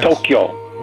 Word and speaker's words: Tokyo: Tokyo. – Well, I've tried. – Tokyo, two syllables, not three.